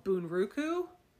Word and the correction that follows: Bunraku: I think is how you say it, right? Mm-hmm. Bunraku? – Yeah.